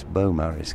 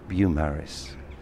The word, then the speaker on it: Beaumaris: An English person would call this Beaumaris Castle, but listening to local people, I think it's actually pronounced Beaumaris.